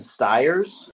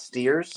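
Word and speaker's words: Stiers: Bradley did say his name incorrectly. It's David Ogden Steers, not Steers.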